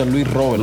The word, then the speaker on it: Luis Robert: En Cuba siempre se pronuncia Luis Robert.